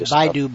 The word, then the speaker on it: Baidu: It's pronounced Baidu, by the way. Everybody's Baidu. – Typing it. – Okay, yeah. Sorry. Baidu. I always go Bidu because I see the word.